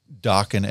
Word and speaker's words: Dokken: so he just called it Dokken as just kind of a placeholder. – Right. – Right, and he's like, it rhymes with rockin', you know, we'll figure out something else later on.